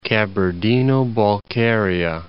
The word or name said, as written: Kabardino-Balkaria